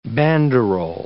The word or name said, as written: banderol